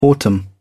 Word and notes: autumn: British English

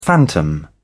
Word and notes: phantom: British English

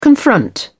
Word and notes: confront: British English

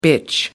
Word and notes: bitch: American English

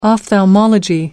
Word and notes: ophthalmology: American English

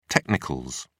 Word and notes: technicals: British English

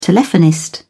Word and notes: telephonist: British English

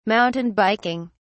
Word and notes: mountain biking: British English

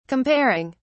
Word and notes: comparing: British English

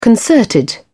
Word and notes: concerted: British English

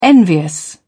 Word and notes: envious: British English

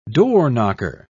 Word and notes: doorknocker: British English

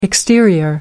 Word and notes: exterior: American English

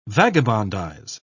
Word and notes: vagabondize: British English

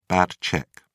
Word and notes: bad cheque: British English

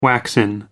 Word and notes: waxen: American English

